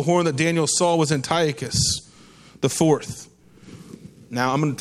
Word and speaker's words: Antiochus: So remember Antiochus. Remember that. That's pronounced right. But Antiochus the IV, Epiphanes of the Seleucid Empire.